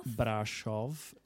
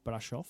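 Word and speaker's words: Brașov: Have I said it right? – Brasov. – It's spelled Brasov, but yeah, Brasov.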